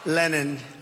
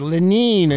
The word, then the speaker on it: Lenin: Lenin.